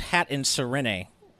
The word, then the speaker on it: Hatterene: A new part of GVG lore now is my, you know, the trauma I experienced Google image searching Hatterene with no safe search on or anything. – I'm a page in, and so far it's not bad. – Oh, sorry. Ditto M says it's pronounced Hat and Serene.